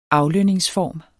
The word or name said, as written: aflønningsform